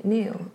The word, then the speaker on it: Nao: Neo eller Now, folk ser lite olika. – Neo, tror jag. – N-A-O. Neo.